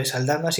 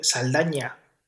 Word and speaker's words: Saldaña: por cierto, me enteré, me enteré ayer de que Zoe Saldana no es Zoe Saldana, sino es Zoe Saldaña. Es con ñ el apellido. Lo que pasa es que, claro, los americanos son un poquito imbéciles y no, no saben pronunciar la ñ.